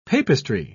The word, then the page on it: papistry: British English